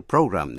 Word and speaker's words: program: Chữ Program đánh vần P-R-O-G-R-A-M có hai cách đọc. Có thể đọc Program hay là Program.